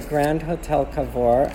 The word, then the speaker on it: Grand Hotel Cavour: So here I am at the Grand Hotel Cavour.